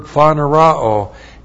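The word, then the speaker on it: phaneroō: For the purpose that when he appears, there's the first use of the verb phanerao. In this paragraph, we have a verb phanerao used five times. P-H-A-N-E-R-O long O. Phanerao.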